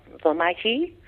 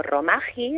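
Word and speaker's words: rōmaji: Y luego tienen un sistema de escritura latino, adaptado al japonés, que más o menos suena como romaji, escrito en castellano lo leeríamos como romaji o algo así, ¿vale? Pero creo que la vocal es larga al final, esa R no la pronuncian como nosotros,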